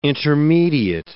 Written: intermediate

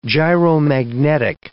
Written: gyromagnetic

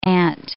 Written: ant

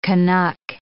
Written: Canuck

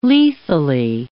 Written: lethally

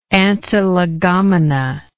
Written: antilegomena

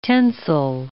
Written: tensile

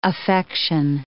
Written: affection

